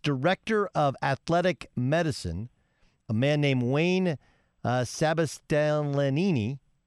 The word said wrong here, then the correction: Sebastianelli: excuse me if I mispronounce his name. – Better you than me. – Okay. Sebastianelli. There we go.